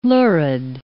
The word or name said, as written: lurid